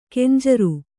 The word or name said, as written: ಖಂಜರಿ